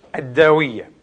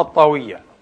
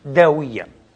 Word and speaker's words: الطاوية: اليوم سنتحدث إن شاء الله عن الداوية، أو كما هي مشهورة الطاوية، لكن الأدق أن تلفظ بالدال داوية، وتكتب بالتائية، وكثيرون يرفضونها بالطاوية، لا بأس.